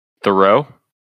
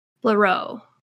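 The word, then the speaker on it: Blériot: Thoreau? – Yep. Leroy. Yeah, that's his name. – Louis Thoreau. Yep. – No, with a B. B-L-E-R-I-O-T. Louis Leroy.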